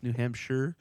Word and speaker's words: Hampshire: That's probably how it's supposed to be pronounced, New Hampshire. It doesn't look like sure.